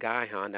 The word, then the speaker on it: Gihon: Some call it Gihon and some call it Gihon. Okay. So this river here, which is called Gihon, that's how I pronounce it.